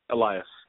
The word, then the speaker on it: Elias: So when does it come to the point that you meet, and I don't, tell me if I pronounce his name wrong, because I don't want to pronounce wrong. Was it Elias? – Elias. – Elias, okay.